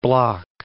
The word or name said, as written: Block Island